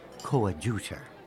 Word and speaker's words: coadjutor: Oh, you're pronouncing it as the British would say it. In America, it's pronounced coadjutor.